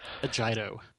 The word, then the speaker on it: Agito: Agito. Soft G. And a hard I.